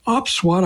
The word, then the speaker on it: OPSWAT: We're going to talk today with Benny Charney, who is founder and CEO of OpsWAT, I believe that's called.